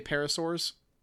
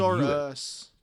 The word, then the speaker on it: Parasaurus: I would say parasaurs. – There's an us at the end. – Yeah, I guess. – It's not S-A-R-S. It's S-A-R-U. Parasaurus. Parasaurus. – But no, it's... Spell? – P-A-R-A-S-A-R-U-S.